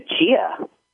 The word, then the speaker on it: chia: So forget, you started another one that I almost forgot to ask you about today, the chai one. – Oh, you mean the chia? – Oh, yeah. How do you pronounce it? – Yeah, chia.